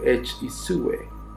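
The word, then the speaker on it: each-uisge: son conocidos como Kelpie o Ichwisge, pronunciado Ichwisge, y comparten ciertos rasgos.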